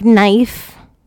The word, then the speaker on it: knife: Like knife. Like k. Knife.